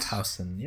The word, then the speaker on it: Schaffhausen: Schaffhausen, yeah. – Schaffhausen. Am I saying that right? Switzerland. Yep.